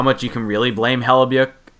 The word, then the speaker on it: Hellebuyck: I just say Hellebuyck. – Yeah, Hellebuyck. – I don't even try with the Y. – Yeah, but just ignore that Y. The Y is silent.